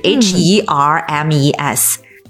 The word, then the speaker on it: Hermes: 那再一个呢，就是爱马仕这个品牌，我们以前在节目里面做过哈，就是 HERMES。但是呢，H 是不发音的，所以呢，爱马仕英文读法是。 – Hermes，爱马仕。